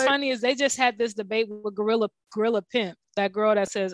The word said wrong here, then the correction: Glorilla: That, oh, Glorilla. – Oh, is that how you say her name? – Yeah, Glorilla. – Oh, girl, I thought it was Gorilla.